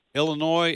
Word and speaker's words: Illinois: And Margaret, when you're out traveling and you hear, especially people south of the Mason-Dixon line, you hear them pronounce the S on Illinois, is that sort of like scratching your fingernails on the chalkboard? – Well, that and also words like often.